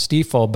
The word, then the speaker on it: Stifel: Stifel and Nicholas, and now just known as Stifel. But yeah, it's it doesn't look like it should be pronounced that way, but it's a German. It's German.